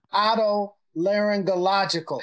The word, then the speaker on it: otolaryngological: But I could not say the word otolaryngological.